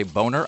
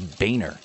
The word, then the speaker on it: Boehner: Just like people say Boner, I think it is Boehner. – I thought it was Bonner.